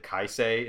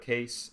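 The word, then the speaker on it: Kaise: I, as Deorn, and I've watched too much anime, I keep wanting to pronounce it Kaisei instead of Case, and I'm just going to keep doing that, sorry.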